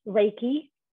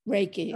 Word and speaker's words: Reiki: Am I pronouncing it right? Because you pronounce it a little different. – I've always been taught that it's Reiki. – Reiki.